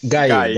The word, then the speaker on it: Gueye: You know, Watford signed somebody that Arsenal had their eye on um, probably gonna butcher the last name. Papa Papa, Papa uh, Gueye from the French League Two. Um, Guy, yes, Guy.